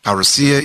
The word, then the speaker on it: parousia: Parousia is spelled P-A-R-O-U-S-I-A, parousia, often translated the coming, the word coming, or the presence of Christ, or his appearing, or his becoming.